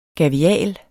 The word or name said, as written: gavial